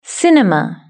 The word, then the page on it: cinema: British English